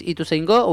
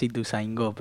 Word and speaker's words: Ituzaingó: Ituzaingó. No sé si es Ituzaingó o Ituzaingó. No sé cómo se pronuncia. – Para mí es Ituzaingó, pero hay algunos que dicen Ituzaingó.